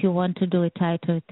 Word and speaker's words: titer: but also I had a specific question about the TETRA test. Is that how that's pronounced? – Titer. – Titer test.